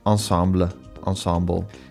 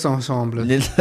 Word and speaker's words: ensemble: Ander favoriet gezelschap van mij, naast het Nederlands Philharmonisch Orkest, is het Nederlands Blazers Ensemble. – Ja, mensen zeiden het is Frans en dus moet je uitspreken ensemble.